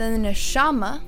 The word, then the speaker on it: neshama: And that word to, he breathed in him pronounced nafak, the breath of life, which is the neshama of high H A Y, the Neshama of high N E S H A M A, the breath of life. So, breathe here is the Neshama of life, High.